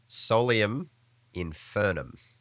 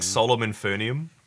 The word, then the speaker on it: Solium Infernum: Sorry, how do you say the game again? – Solium Infernum. – Oh, he nailed it. – Look at me go. You should go and watch it because I do mispronounce the title of the game twice in front of two of the people who created it. So I'm You're saying like Solium Infernum? – Yes, that's exactly what I did.